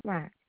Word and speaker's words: Slack: Lisa Slack, S as in Samantha, Lack.